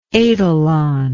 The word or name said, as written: etalon